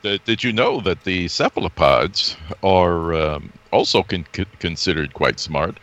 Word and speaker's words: cephalopods: Well, they're all called cephalopods. Or cephalopods. – Okay, I had the accent on the wrong syllable there, didn't I?